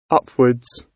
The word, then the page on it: upwards: British English